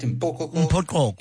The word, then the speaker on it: Mpoko: They knew how to give pap, mpopo, how do you say it? – Mpoko. – You know, that pap with milk. – Yep. Mpoko. Yep.